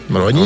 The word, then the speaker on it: Маруани: Маруани. Он француз, на последний слог ударения.